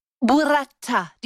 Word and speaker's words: burrata: Burrata.